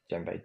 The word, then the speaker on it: djembe: Djembe, D-J-E-M-B-E.